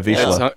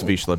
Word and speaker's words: Vizsla: Is that how you say it? – Yeah, Vizsla. – It's Hungarian, so I think you're right. – Yeah. It's Vizsla.